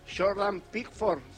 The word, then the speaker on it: Pickford: Jordan Pickford será el arquero.